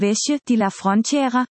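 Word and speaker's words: Vejer de la Frontera: og Vejer, udtales Vesje de la Frontera, som alle ligger i det smukke landskab mellem Malaga og Cadiz.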